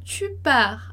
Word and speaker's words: pars: Tu pars à quelle heure? Tu pars à quelle heure? Então ali a gente vai dizer tu pars sem pronunciar o S.